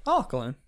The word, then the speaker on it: Auckland: Auckland. – There we go. – It's like Falkland, but with an A. – Oh. It's like Falkland, but with an A. – Auckland.